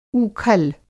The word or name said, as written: ऊखल